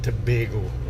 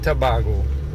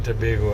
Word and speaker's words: Tobago: Just to correct you on the pronunciation, it's Trinidad and Tobago, not Tobago. Trinidad and Tobago, and yes, we do speak English first with an accent.